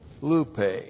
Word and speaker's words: lupe: So I have great, point three, lupe. L-U-P long E. Lupe.